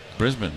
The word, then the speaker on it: Brisbane: Brisbane, yeah.